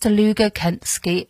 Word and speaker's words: Dlugokecki: D-L-U-G-O-K-E-C-K-I. But it is pronounced Deluga Kensky. I can assure you.